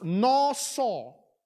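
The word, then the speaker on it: naw saw (נשא): The word take in Hebrew is pronounced "naw saw. All right? If you want to pronounce, write it down phonetically N A W S A W, naw saw.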